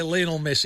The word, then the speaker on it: Lionel Messi: So is it, it's Lionel Messi, isn't it? Or can you say Lionel Messi? – No, you can't say Lionel. – You've got to say Lionel Richie. Yeah. We're not dancing on the ceiling. – We've got to say Lionel Messi. – It's Lionel. That's the correct pronunciation.